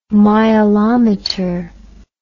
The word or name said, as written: mileometer